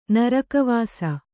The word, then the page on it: ನರಕವಾಸ: kannada